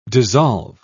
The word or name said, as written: dissolve